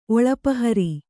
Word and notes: ಒಳಪಹರಿ: kannada

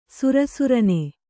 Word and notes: ಸರಸರನೆ: sara sarane

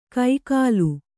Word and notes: ಕೈಕಾಲು: kai kālu

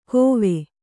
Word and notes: ಕೋವೆ: kōve

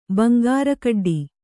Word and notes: ಬಂಗಾರಕಡ್ಡಿ: kannada

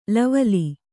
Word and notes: ಲವಲಿ: lavali

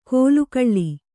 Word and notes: ಕೋಲುಕಳ್ಳಿ: kōlu kaḷḷi

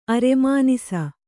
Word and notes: ಅರೆಮಾನಿಸ: Kannada

